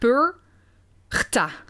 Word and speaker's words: Perchta: Ja, het is eigenlijk Purgta. – Purgta. Ja. – En je schrijft Purgta. Het als P-E-R-C-H-T-A.